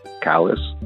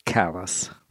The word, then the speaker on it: Calais: Vermont is not France. Montpelier is not Montpellier. And Calais is not Calais. – It is Calais, Calais, not Calais, or some other French pronunciation. And I know because I live here.